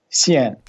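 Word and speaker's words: xian (限): Xian. – Xian. That's the way to pronounce it. It's the fourth tone, so we go down.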